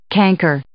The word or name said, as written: canker